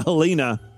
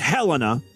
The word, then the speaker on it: Helena: Well, this it's it's pronounced Helena, not Helena, and you got to say the stupid name twice.